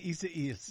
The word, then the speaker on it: Isaias: Hurricane, how do I say this, Desi Duane? – Isaias. – I see what you're doing. You're laughing Isaias. – At me. Hurricane Isaias. – There you go.